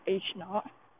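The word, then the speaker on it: H₀: Hypothesis is called, well, the notation for hypothesis is H-naught, basically H-zero, but you pronounce it like H-naught.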